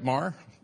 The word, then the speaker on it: Maher: Did I get that? Say it right, Maher? I said it wrong the first service, and dear Pastor Taryn got me straightened out here for the second.